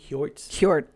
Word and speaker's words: Hjortsberg: It's based on a novel by William Hjortsberg. I think it's how you say it, right? When it's H.J. – Hjortsberg. Hjort.